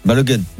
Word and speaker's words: Balogun: Que Balogun aux États-Unis se prononce Balogun. – Balogun, bien sûr.